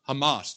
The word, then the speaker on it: Hamas: And it had, how do you pronounce the Hamas? Hamas, thank you.